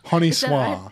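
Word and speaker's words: Honi Soit: I've got to get the pronunciation of this magazine right because it's spelt Honey Soit, but it's not. What is it again? – Honey. – Honey. Honey soir.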